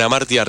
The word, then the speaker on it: Hamartia: Compren Amartya, recuerden, se escribe con H.